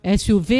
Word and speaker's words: SUV: Como que se pronuncia isso? É SUV? – Um SUV, né? – SUV? É, SUV. – É SUV, né? SUV. É SUV. Atingiu